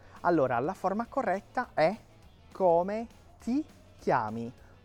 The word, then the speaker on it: come ti chiami: Não é come ti chiami, não come ti chiami. Essa é uma dica de pronúncia importante. Quando eu tenho TI, o som é ti, é um T seco, ok? Eu vou fazer esse leve sorriso com a boca, ti.